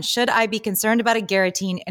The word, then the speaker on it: agaritine: The first one is, Should I be concerned about agaritine? Did I pronounce that right, Julia? – I think it's agaritine.